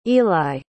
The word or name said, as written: eli